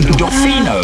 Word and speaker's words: Dofino: Dofino. – Dofino. So spell that for me. – D-O-F-I-N-O.